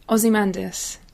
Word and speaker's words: Ozymandias: Ozymandias.